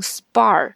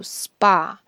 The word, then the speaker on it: spa: 啊，或者休闲健身啊这种地方，那么很多同学会把它读成 spa。现在你应该知道了，这边根本就没有 r，所以你是不可能读出 r 的这个音的，应该读 spa。